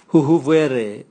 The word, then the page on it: huhuvwere: Raga